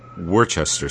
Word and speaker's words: Worcester: Although, what's funny is, here in the United States, most people pronounce it Worcester sauce. – Yeah. – Yeah. – You pronounce it the way it kind of should be said, I guess.